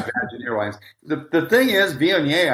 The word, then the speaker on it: Viognier: So for those who don't know, Viognier is a hard wine to pronounce, and it's V-I-O-G-N-I-E-R, Viognier. And people pronounce it all different ways, like Bognier and all sorts of things.